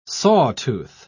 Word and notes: sawtooth: British English

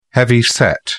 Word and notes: heavyset: British English

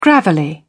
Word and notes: gravelly: British English

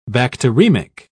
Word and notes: bacteremic: British English

